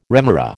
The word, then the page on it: remora: American English